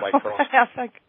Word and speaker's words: microscopist: Am I saying that right? – That's one way of saying it. Called a microscopist. – How do you pronounce it? – Microscopist. – Microscopist. Okay, I left out a few syllables.